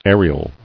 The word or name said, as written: Ariel